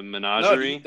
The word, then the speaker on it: Menagerie: And then now we have, I'm going to mispronounce this, the Menagerie. – No, that is correct, perfect. – Correctly. Said it correctly.